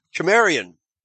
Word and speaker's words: Cimmeria: The letter C throughout history before then was pronounced like a letter K, as in Cimmerian, spelled C-I-M-M in English, but still pronounced Cimmeria, or in Greek, Cimmerioi, with a K.